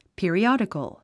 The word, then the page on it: periodical: pìəriάdikəl; ピリオディコォ